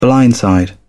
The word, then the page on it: blindside: British English